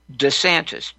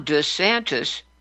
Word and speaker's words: DeSantis: You know, we've all made the switch to pronouncing his name DeSantis, DeSantis. But Ron DeSantis pronounces his own name in his own announcement as DeSantis. What is going on? He reverts to his former pronunciation of his own name, DeSantis.